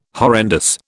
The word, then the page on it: horrendous: British English